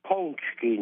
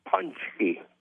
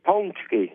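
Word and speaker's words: pączki: The correct pronunciation is Ponchki, not Punchki. Say it again. Ponchki. Like P U N G, Pongski? Pongski. Ponchki. Ponsky.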